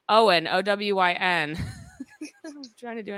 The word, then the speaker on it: Owyn: O-W-Y-N, pronounced like Owen, only what you need.